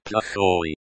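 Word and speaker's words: Plakhoy (плохой): Plakhoy can be tricky, because the O is pronounced like the O in hot, and the Y is pronounced like the Y in yes, and not like the Y in fly.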